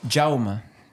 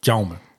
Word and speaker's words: Jaume: Yes, det er Jaume. Jaume.